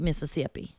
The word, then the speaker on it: Mississippi: No, they do pronounce all the syllables there in that state, Mississippi.